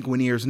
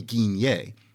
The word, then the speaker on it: Guinier: For example, Lani Guinier's name isn't pronounced Lani Guinier. Instead, it's pronounced Lani Guinier, as in that word Guinier distorted by Anglophone mouths into Guinier, like that.